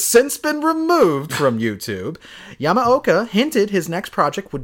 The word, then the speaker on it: Yamaoka: Yamaoka, yeah.